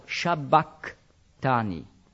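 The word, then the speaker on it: sabachthani: Dann kommt ja ein Chi. Und das hat man im Altgriechischen nicht als Ch, wie im Schweizerdeutschen, ausgesprochen, sondern eher als K. Also Shabakhtani.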